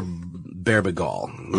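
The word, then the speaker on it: Bebergal: It's a book called Season of the Witch, How the Occult Saved Rock and Roll by a guy named Peter Berbigol. Pronounce it like that.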